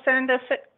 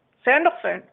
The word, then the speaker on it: Sandalphon: It's also the color of Archangel Sandalphon. I can't say his name today. Sandalphon.